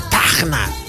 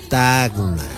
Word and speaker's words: Tacna: Y vencían pues los tacneños, decían: no, oye, no se dice Tacna, se dice Tacna.